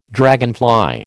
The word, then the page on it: dragonfly: American English